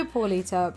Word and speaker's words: Paulita: So, Paulita, please tell our curious fuckers a little bit about yourself.